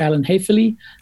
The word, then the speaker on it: Alan Haefele: Slight pronunciation on the name, but Alan Hafely.